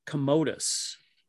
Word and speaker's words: Commodus: Were appropriated by Emperor Commodus. That's how you say it.